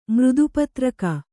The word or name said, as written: ಮೃದುಪತ್ರಕ